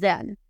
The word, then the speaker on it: dead: Como el gato está muerto, the cat is dead. Ojalá nunca les toque decir esa frase, pero si les toca decirlo, pues dead, bien pronunciado, de al principio, de al final. Dead, dead, que suene la D.